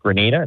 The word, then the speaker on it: Grenada: I guess Grenada.